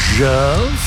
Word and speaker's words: Djerv: A band called Jerv. You're spelling that D-J-E-R-V. So you need to utilise the silent D option in your pronunciation, just FYI. It's a great song. It's called Rainbreaker. Here on Karenga. Rain spells R-E-I-G-N as opposed to weather-based rain.